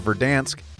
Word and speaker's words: Verdansk: The Steelers kept Dave out of Verdansk.